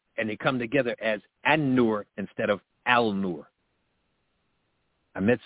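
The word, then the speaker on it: An-Nur (النور): Al-Nur, or correctly pronounced, An-Nur, the light. An-Nur, the light. Al-Nur is not all the way wrong, but in Arabic language, when there's a proper noun that starts with an N sound,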